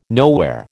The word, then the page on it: nowhere: American English